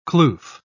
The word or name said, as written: kloof